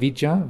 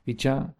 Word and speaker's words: vijja: So in that respect, I like to use the word vicha, the Pali word vicha, V-I-J-J-A, long A at the end, vicha, as, say, representative of that quality of awakened awareness.